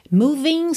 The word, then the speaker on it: moving: Moving seria M-O-V-I-N-G. Esse G não é pronunciado,